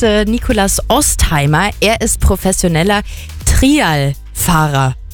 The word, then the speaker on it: Trialfahrer: Ja, weil man hat ja immer Trail irgendwie so im Kopf. Und jetzt wird das aber andersrum geschrieben und dann war ich ein bisschen verwirrt. Also Trialfahrer ist der richtige Begriff.